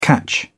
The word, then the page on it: catch on: British English